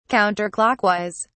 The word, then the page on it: counter clockwise: British English